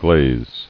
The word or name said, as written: Glazer